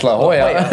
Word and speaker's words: La Jolla: Which I found out after moving there is pronounced La Jolla.